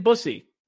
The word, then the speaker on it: Bussi: No Brandon Bussy, none. And that's exactly how it's pronounced, B-U-S-S-I. That's exactly how it is.